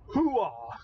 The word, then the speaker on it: hooah: But it's the fact that you actually put the emphasis on the last syllable. Almost nobody does that. They usually go, oh, no, as drilled into your head in army basic training is hooah. – Mm-hmm. – Emphasis on that last syllable. Most people don't put there.